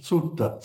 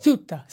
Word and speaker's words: Suetta: His name is S-U-E-T-T-A. Sutta? Is that how you pronounce it? S-U-E-T-T-A. – Sutta. – Sutta. See, he had it better than I did.